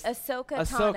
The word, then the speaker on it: Ahsoka Tano: Asuka Tano, that's the right way to pronounce it? – Oh, no, it's hard. It's Ahsoka Tano. – Ahsoka Tano, thank you. – That's okay. Everyone mispronounces it.